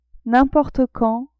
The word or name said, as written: n'importe quand